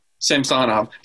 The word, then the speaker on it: Samsonov: Samsonov. Apparently that's how you say it.